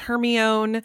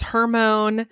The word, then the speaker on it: Hermione: Some people told us it was Hermone. – Hermone. – Yeah. It honestly wasn't until, I don't think it was until the movies came out that I learned the right way to say her name.